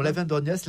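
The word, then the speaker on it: Aven d'Orgnac: On continue nos pérégrinations souterraines à Lavandorniac, c'est comme ça qu'on prononce ? – Absolument, Lavandorniac, là on est dans le sud du département,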